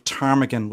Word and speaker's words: ptarmigan: And I remember my late father telling me he was very impressed that I knew even at that age that the P at the start of ptarmigan was silent. So that's not bad for a 5-year-old. – Yes, ptarmigan is spelled P-T-A-R-M-I-G-A-N.